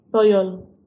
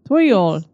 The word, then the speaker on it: Toyol: How do you say it? – Toyol. – Toyol. – Toyol. – Yes.